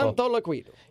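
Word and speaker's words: Santoliquito: Santa Santa Liquido. Santa Liquido article. Santo Liquido.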